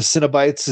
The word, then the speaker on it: Cenobite: I know, and I know it's pronounced Cenobite, but I think cinnamon roll.